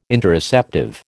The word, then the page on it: Interoceptive: American English